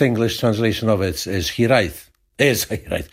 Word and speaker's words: hiraeth: Is it hiraeth or hiraeth? – Hiraeth.